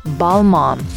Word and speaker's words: Balmain: It's actually written Balmain. Some people say it as Balmain, but it's actually pronounced Balmain.